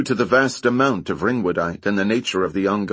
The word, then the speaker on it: ringwoodite: "Z-waves caused by the water being released from a mineral known as ringwoodite."